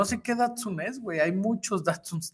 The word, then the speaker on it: Datsun: ¿Qué le puedo hacer un Daxun? Puedes pronunciar bien su nombre, Sergio. es Datsun, con de Tito.